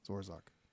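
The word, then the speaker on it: Zor'zauk: Well, in the Dragonborn language, it's Z-O-R. The Z is pronounced with an S and then apostrophe Z-A-U-K. – So I totally misspelled that. – How do I spell that? – Z-O-R. That first Z is sounded like an S. – Uh-huh. – Then it's Zoc. Z-A-U-K. Zorzoc.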